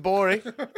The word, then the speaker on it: Gborie: David Gborie, at the G is silent.